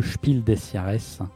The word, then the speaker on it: Spiel des Jahres: Le premier étant le fameux Spiel des Jahres, si je le dis bien dans la langue de Goethe. – Très bien dit, très bien Merci.